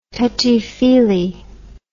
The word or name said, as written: touchie feelie